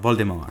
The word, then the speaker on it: Voldemort: Anche perché in, in realtà la Rowling l'aveva immaginato come Voldemort, no? un po' alla francese. Voldemort, Voldemort Quindi sì.